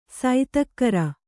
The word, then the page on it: ಸೈತಕ್ಕರ: saitakkara